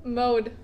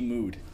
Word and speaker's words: Depeche Mode: That's Depeche what? – Mode. – Ooh, sorry, it's Depeche Mood.